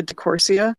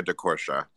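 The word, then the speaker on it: diCorcia: Lately, I've just had a renewed obsession with Philip Lorca de Corsia, or de Corsia. I'm not sure how you say it. Are you guys familiar with him? – Yeah, Philip Lorca de Corsia. – De Corsia, thank you.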